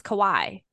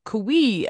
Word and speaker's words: Kawhi: Yeah, Kawhi Leonard. – I would like ESPN always is like Kiwi, and I was like, it's not Kiwi.